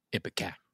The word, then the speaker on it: ipecac: Ipecac.